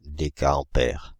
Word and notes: décaampère: [de.ka.ɑ̃.pɛʁ]

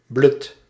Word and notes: broken: American English; [ˈbɹoʊ.kən]

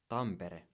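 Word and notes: Tampere: Finnish

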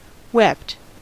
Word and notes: wept: American English; /ˈwɛpt/

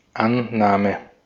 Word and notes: acceptance: American English; /ək.ˈsɛp.təns/

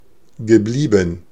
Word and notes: geblieben: /ɡəˈbliː.bn̩/

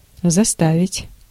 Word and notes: force: /ˈfɔːs/; fɔəs/; /ˈfɔɹs/; foəɹs/

